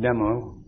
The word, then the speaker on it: Tummo: It's spelled T-U-M-M-O. it's pronounced demo, because the D is only the letter T, substantialized, and it refers to the heat that you develop in your tum.